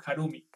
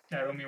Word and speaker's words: Jarumy: Ay, qué bueno, qué bueno, estoy pronunciando bien tu nombre. ¿Yarumi o Harumi? – Yarumi. – Yarumi, buenísimo, buenísimo.